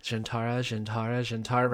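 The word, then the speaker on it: zhian'tara: So I was like, Jintara, right before we recorded it, so it was fresh.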